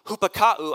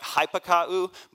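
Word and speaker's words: Hupakau (ὑπακούω): Hupakau, I know it looks like hypakau, but in the Greek, the Y is pronounced like a U.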